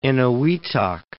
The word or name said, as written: Eniwetok